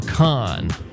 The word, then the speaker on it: Kahan: Do you have any Noah Kahan? I'm not sure if I'm pronouncing his name right. – Oh, Noah Kahan. – Yeah, like con artist. – Yeah, that's it.